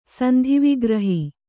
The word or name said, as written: ಸಂಧಿವಿಗ್ರಹ